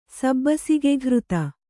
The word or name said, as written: ಸಬ್ಬಸಿಗೆಘೃತ